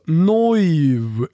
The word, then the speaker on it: Neuw: N-E-U-W. Alltså Neuv blir ju på tyska.